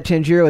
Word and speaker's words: Tanjiro: Tenjiro Kamado? – Sure. – Tenjiro? Mm-hmm. Tenjiro Kamado? – Yeah, okay. Yeah. – Though even a casual manga fan would know that Tanjiro is popular, a new study suggests his popularity actually far exceeds all expectations.